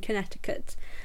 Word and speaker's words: Connecticut: Connecticut. Is that how it's said? Yeah, in Connecticut.